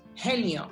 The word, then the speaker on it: Haenyo: En los mares de la isla de Jeju bucean las Henyo, que se escribe Haenyo, que son unas abuelas buceadoras de la isla de Jeju, que son unas auténticas guerreras.